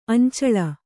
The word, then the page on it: ಅಂಚಳ: Kannada